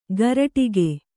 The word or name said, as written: ಗರಟಿಗೆ